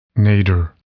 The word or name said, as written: nadir